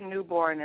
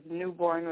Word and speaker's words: Newborne: It is Talonda Newborn, and let me spell it for you, please. It's T-H-O-L-A-N-D-A, and my last name is Newborn with an E on the end, N-E-W-B-O-R-N-E.